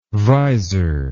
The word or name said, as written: vizor